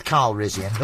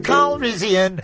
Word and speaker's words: Calrissian: No, nothing rhymes with Carl Rizzian, does it? – We've had this before. – Oh, yeah, you clever clogs came up with Parisian. – Yeah, it rhymes with it.